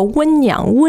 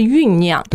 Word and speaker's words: 酝酿: 以前我的一个老师他就经常说温酿温酿，然后我想他在说什么呢，其实他标准的读音应该是酝酿。